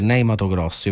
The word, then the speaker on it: Ney Matogrosso: che è Ney Matogrosso. Io faccio la pronuncia così italiana. – Hai detto benissimo.